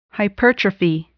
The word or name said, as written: hypertrophy